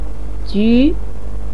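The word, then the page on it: ju: tone 2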